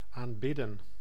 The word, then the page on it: aanbidden: [am.'bɪ.də(n)]; /aːnˈbɪdə(n)/